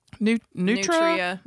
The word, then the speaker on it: nutria: Yeah, Nutra. – Nutria. – Nutria. – Nutria. – Yeah. – Nutria. – It's Nutria, but you say it Nutra.